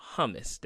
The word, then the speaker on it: hummus: He used to purchase this thing called hummus. Did I pronounce it right, Daniel? Hummus? – You did.